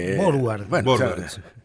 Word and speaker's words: Vorwärts: Me parecía el inglés, en vez de forwards es... – Exactamente. – Vorwärts. – Vorwärts. – Exactamente, ahí está la pronunciación correcta.